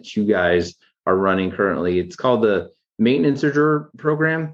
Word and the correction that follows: Maintager: It's called the Maintager Program, which is maintenance and managers put together, kind of part of each.